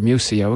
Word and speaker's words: Muscio: Just Google Richard Muzio. Again, last name is spelled M-U-S-C-I-O. So Google Richard M-U-S-C-I-O YouTube.